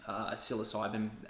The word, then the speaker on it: psilocybin: psilocybin being used for depression and so many more things.